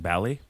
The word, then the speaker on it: Bali: Toni Braxton is like, I pronounce it Bally. And so then the director's I've been there. – Cool. So we're going to, everyone has to, it's Bally now.